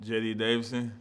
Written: JD Davison

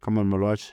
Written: Khaman Maluach

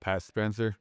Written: Pat Spencer

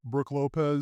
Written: Brook Lopez

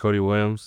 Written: Cody Williams

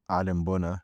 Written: Adem Bona

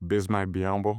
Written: Bismack Biyombo

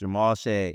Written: Jamal Shead

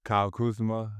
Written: kyle KUZMA

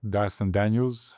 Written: Dyson Daniels

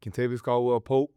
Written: Kentavious Caldwell-Pope

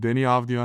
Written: Deni Avdija

